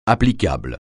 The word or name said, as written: applicable